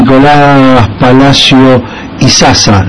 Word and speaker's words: Nicolás Palacio Isaza: vamos a hablar con Nicolás Palacio Isaza.